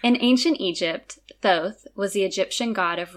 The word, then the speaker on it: Thoth: If you're not super familiar with the history of ancient Egypt, the god Thoth, also sometimes pronounced Tot or Thoth,